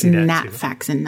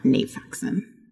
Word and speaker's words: Nat Faxon: So also, I nice think it's Nat Faxon, not Nate Faxon.